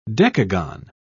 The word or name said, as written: decagon